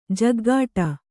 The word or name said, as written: ಝಾಗಟಿ